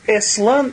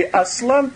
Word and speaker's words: Аслан: И в этом царстве главным царем является некий лев по имени Эслан или Аслан, по-разному его, значит, произносят.